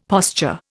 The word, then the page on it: posture: Indian